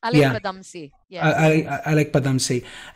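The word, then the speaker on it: Alyque Padamsee: And how do you pronounce it? Alik Padmasi? – Alek Padamsi.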